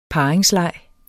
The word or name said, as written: parringsleg